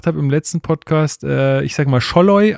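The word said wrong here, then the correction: Szoboszlai: aber er heißt an, äh, anscheinend Zolloy. Also mit Z vorne oder Z gesprochen.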